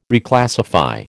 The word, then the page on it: Reclassify: American English